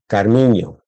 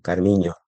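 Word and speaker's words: Carminho: Carmiño, que la ñ en portugués se escribe NH, Carmiño.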